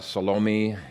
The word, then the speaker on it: Salome: As I've already mentioned, Salome is normally pronounced Salome. Some people pronounce her name Salome, and the Greek actually is Salomein with an N on the end. I think all three pronunciations have the emphasis on the second syllable, but the standard really is Salome, at least in the English language.